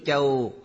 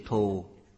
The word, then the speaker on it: Thù: Chữ Châu có hai cách đọc. Chữ này còn đọc âm Su, tức chữ thù trong thù thắng.